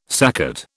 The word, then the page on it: saccate: British English